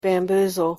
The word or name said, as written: bamboozle